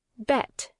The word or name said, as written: bet